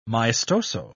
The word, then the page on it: maestoso: British English